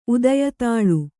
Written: ಉದಯತಾಳು

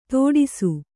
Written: ತೋಡಿಸು

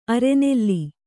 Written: ಅರನೆಲೆ

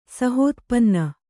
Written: ಸಹೋತ್ಪನ್ನ

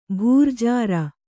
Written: ಭರ್ಜರಿ

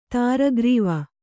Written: ತ್ರಿಗಾರವ

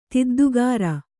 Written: ತಿದ್ದುಗಾರ

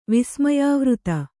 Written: ವಿಸ್ಮಯಾವೃತ

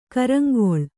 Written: ಕರಾಂಗುಳಿ

